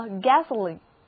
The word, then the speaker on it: gasoline: 那 gas 作为汽油讲的时候还有一个近义词也表示汽油，叫做 gasoline。 gasoline, G-A-S-O-L-I-N-E, gasoline. 注意元音字母 O 在这里读作短的 a，而另外一个元音字母 I 则读作长的 e， gasoline。